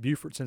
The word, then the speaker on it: Beaufort: And I'm glad that we mentioned Beaufort, because Beaufort, North Carolina, and Beaufort, South Carolina are spelled just the same. Both are very neat little towns, but they are pronounced differently.